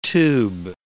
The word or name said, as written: tube